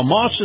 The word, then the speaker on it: Maus: Now, Moss is spelled M-A-U-S,